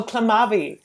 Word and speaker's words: Clamavi: There's, you mentioned not wasting words, which I agree with, by the way, but I don't know how to say the title of the poem. Clamavi? – Oh, Clamavi. – Yeah. Oh, God. Is that kind of right? Okay. So this, well done.